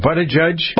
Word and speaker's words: Buttigieg: how do you pronounce his name? – Buttigieg.